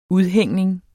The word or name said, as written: udhængning